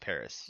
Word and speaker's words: PVRIS: PVRIS, but it's pronounced Paris.